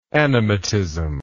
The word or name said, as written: animatism